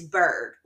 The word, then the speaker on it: Bourg: It's Bourg, like the lettuce, iceberg.